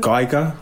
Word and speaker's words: Geiger: I mean, to think, as I said, where you started like a few months ago, you just did a photo shoot with Kurt Greiger, Grieger. I don't know how to pronounce it. I think it's a Grieger. Is um, it uh, Greiger? – Geiger, I believe. Geiger, Geiger even yeah. even worse. Yes, I, I got it wrong both times.